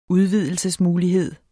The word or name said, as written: udvidelsesmulighed